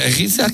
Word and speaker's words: Giza: Giza.